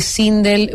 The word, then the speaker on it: Sindel: Gracias a Val Castro, Ralph Pérez, siempre está con nosotros, y Sindel Vallejo. Lo dije bien, Sindel, así es. Él me corrigió el otro día que le pusiera el acento.